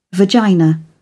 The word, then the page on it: vagina: British English